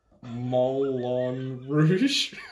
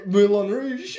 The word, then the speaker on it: Moulin Rouge: Moulin Rouge.